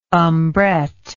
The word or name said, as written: umbrette